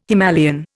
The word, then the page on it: Himalayan: Indian